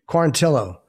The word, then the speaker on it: Quarantillo: And that's how, and also Quarantillo, is that how you say it? – Quarantillo.